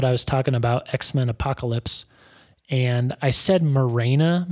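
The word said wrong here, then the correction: Moira: McTaggart, and I knew what her name was. I just pronounced it wrong. – Right. – It's Mora. Mora McTaggart.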